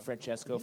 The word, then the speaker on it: Francesco: How do you pronounce Francesco. – It? Francesco Francavilla.